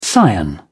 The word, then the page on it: scion: British English